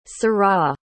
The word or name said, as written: Seurat